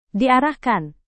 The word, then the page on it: diarahkan: Indonesian